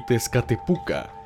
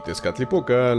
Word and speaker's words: Tezcatlipoca: Al no poder pronunciar Huichiloposli y Tezcatlipoca, los conocían como Huichilobo y Tezcatepuca.